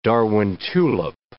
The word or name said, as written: Darwin tulip